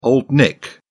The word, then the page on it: Old Nick: British English